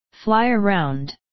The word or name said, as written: fly around